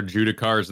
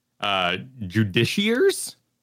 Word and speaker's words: Judiciar: Oh, I learned from Baldur's Gate that it was never Judicars in the first place, which I think is a badass name, but it was never pronounced that way, apparently. – How is it pronounced? – Judiciars, which makes it sound a bit more, you know, judgmental.